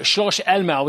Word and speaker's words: Schloss Elmau: And by the way, you didn't butcher that name. Schloss Elmau was a very, very good pronunciation, Brian, as well. So 10 out of 10 on that one.